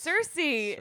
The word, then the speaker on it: Cersei: Do you really say Cersei? – I don't know. – It's Cersei.